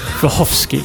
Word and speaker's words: Wachowski: Wachowski. – Wachowski.